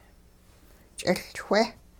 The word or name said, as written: ch'iLchwe